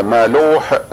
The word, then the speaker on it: مالوه: مالوه، وبعضهم يقول مالو بدونها، ولكن اللغة الهندية فيها هاء مخففة تلحق بعض الكلمات لا يلاحظها بعض الناس، فهي تنطق الآن مالوح بالهاء.